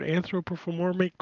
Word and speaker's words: anthropomorphic: They're all anthropomorphic. How do you say that word? Anthropomorphic, whatever. – Anthropomorphic. – There you go.